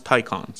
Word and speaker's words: Taycan: It's spelled T-A-Y-C-O-N. It looks like Taycan, I've heard that it is pronounced Taycan.